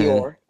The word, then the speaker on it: Lyor: Leo Lyor, however you say his name, Cohen. – Lyor. – It's Lyor.